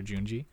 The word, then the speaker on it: Junji: Everybody, he is Junji Ito, or Junji Ito, however you want to pronounce it, Junji or Junji. I'll say Junji when I say it, but he is probably the father of Japanese horror manga.